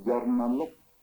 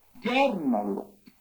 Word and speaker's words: Yarnalop: No es como él, bueno, la pronuncia que dice Yarnalop, sino Yarnalop. Es acentuando el ya.